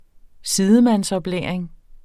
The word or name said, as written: sidemandsoplæring